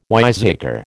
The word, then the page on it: wiseacre: American English